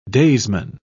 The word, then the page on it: daysman: British English